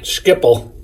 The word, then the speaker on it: Schiphol: Am I saying that right? – Schiphol.